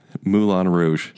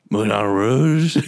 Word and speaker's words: Moulin Rouge: Wait, that's not how you say it. – Oh. Moulin Rouge. – That's right. That's exactly right.